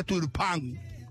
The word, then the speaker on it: Turpin: Quiero mostrar la imagen de Turpin, se escribe. Pero en francés se pronuncia Turpin.